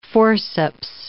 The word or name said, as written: forceps